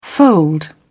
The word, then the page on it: fold: British English